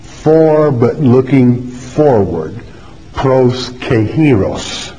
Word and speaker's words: Kairos: K-A-I-R-O-S, pronounced Greek, transliteration, pronounced Kairos, means opportunity.